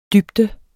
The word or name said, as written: dybde